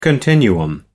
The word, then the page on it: continuum: American English